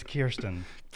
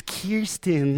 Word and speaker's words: Kirsten: No, it's Kirsten. – It's Kirsten.